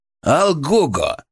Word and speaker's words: Алгого: «Алгого!» — он сделал ударение на втором слоге.